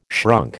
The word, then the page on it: shrunk: American English